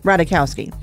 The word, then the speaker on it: Ratajkowski: Ratajkowski. Yeah. – The J is silent.